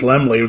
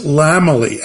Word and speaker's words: Laemmle: Yeah, well, there was Dorothy Parker always said, if you want to know how to pronounce the name correctly, which was not she never didn't say it was Lemley. It's like, it's Lamely, as in he has a very large family.